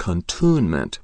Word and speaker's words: cantonment: A few words with which I was unfamiliar is cantonment, which is a military compound, pronounced contunement by the British.